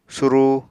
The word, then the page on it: surúu: Apatani